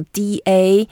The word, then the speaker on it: 打: Okay, so da is super useful. It's also easy to pronounce. It's like a D-A, third tone, da.